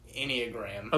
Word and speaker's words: Enneagram: How do you say it, producer? – I'm looking it up. I think it's pronounced Enneagram. – Okay.